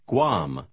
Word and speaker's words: Guam: Иногда комбинация G-U-A в начале слова звучит как «г». Попробуйте прочитать номер 9. – We like Guam.